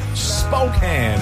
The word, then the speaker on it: Spokane: Spokane, it's pronounced.